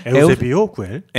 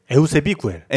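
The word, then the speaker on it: Eusebi Güell: 그 사람을 특정하자면 우리가 앞으로 얘기할 구엘은 다 에우세비 구엘이에요. – 네네. 네. 에우세비오 구엘. – 에우세비 구엘. – 에우세비 구엘. – 에우세비오. 그러니까 안토니 가우디, 안토니오 가우디 이렇게 많이 부르잖아요. 그러니까 이걸 이제 카탈루냐식으로 발음하느냐 아니면 마드리드식으로 발음하느냐.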